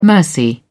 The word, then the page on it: mercy: British English